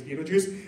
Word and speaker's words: Betelgeuse: And during that tour, we learned about a star called Betelgeuse, spelled Betelgeuse, which just doesn't look like you pronounce it as Betelgeuse. Anyway, it's a star called Betelgeuse.